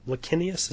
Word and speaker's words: Licinius: This one, a guy named Licinius. Is that how you say his name? – I think so. It works.